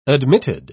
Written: admitted

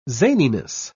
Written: zaniness

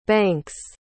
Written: banks

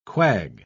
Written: quag

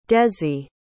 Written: dezi

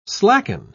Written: slacken

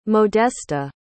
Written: modesta

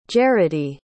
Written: gerodi

